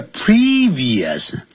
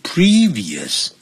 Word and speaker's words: previous: By their previous owners，这个 their 所有格，那这个代词呢，所有格指的就是呢前面的这些 goods，啊，这些大部分的这许多东西呢都是原先的主人 previous，不要念成 previous 或者 previous，这叫 previous，发 e 的长音 previous，原先的这些主人给扔掉了。